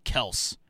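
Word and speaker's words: Kelce: Actually, fun fact, it's actually pronounced Kels. That's from their mouth. So I somehow along the way, somebody called them Kelsey, and they just stuck with it. But it actually is Kels.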